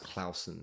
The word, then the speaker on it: Clausen: And my surname actually should be pronounced Clausen. So really? – Yeah, but we anglicized it during the Second World War so people didn't think we were German.